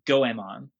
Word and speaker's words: Goemon: But it is technically pronounced Goemon.